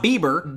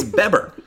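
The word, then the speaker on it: VanBebber: It's not Bieber. – Jim Van Beber. – It's Beber.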